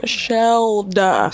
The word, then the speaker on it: Shelda: Shelda.